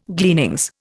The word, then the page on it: gleanings: Indian